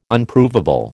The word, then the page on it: Unprovable: American English